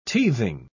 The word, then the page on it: teething: British English